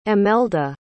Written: emelda

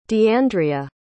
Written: deandria

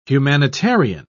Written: humanitarian

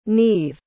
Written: niamh

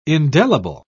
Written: indelible

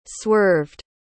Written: swerved

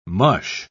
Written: mush

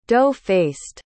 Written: dough-faced

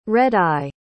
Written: red-eye

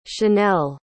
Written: shanell

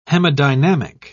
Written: hemodynamic

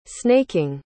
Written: snaking

